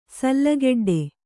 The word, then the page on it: ಸಲ್ಲಗೆಡ್ಡೆ: Kannada